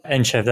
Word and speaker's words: Entchev: I believe I pronounced that right, Delian. Is that the correct way to pronounce your surname? – Entchev, that's it, yes.